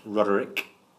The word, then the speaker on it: Roderick: I was only Roderick Makem on Facebook. – You are unequal. – And so, at Roderick Makem on Twitter. And Roderick or Roderick? – Roderick. – Spell it. – R-O-D-E-R-I-C-K. – It's a good old-fashioned name. And Makem, M-A-K-I-M? – M-A-K-I-M.